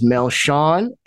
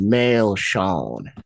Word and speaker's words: Melchion: My name is Melchion, and the way they pronounce it down there is Melchion.